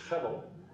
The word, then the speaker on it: hevel: The word in the Hebrew is hevel. Guess what? This is H-E-V-E-L. And H's, they're often pronounced as if you're clearing your throat. Say hevel.